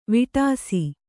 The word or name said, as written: ವೇಡಿಸು